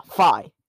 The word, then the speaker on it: Fi: It's pronounced Fi in America. – I never know how to pronounce her name. – I'm telling you definitively, it is made out of two letters in hiragana. Fa and E, which together make the name Fi.